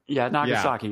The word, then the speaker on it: Nagasaki: I forgot even how you pronounce it. – Nagasaki. Yeah. You're right.